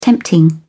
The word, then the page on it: tempting: British English